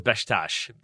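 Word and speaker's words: Beşiktaş: But the way I was taught was Besiktas, basically, as like two syllables. You say it very fast, but Besiktas is what I was taught at least.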